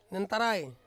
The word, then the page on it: nentarai: Ansus